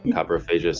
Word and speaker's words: coprophagia: And Kathy is wondering if you have any suggestions for coprophagia, I hope I pronounced that correctly, but please correct me if I'm wrong, otherwise known as eating feces. – No, well done with the pronunciation, coprophagia.